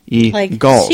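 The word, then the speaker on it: Eagulls: Not The Eagles, but it's spelled E-A-G-U-L-L-S. E-Gulls. – Like seagulls, but E-Gulls. – Right, seagulls without the S.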